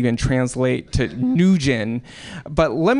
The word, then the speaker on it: Nguyen: That's Nguyen, because Vietnamese is a monosyllabic language. There is one syllable per word.